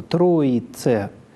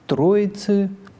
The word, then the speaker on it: троице: Следующее слово «троице» в церковном произношении, «троицы» в литературном. Заударное «и» в литературном произношении редуцируется, и в силу «ыканья» последний звук представлен как «ы» в литературном произношении, в то время как в церковном это «э», как было, так и остается.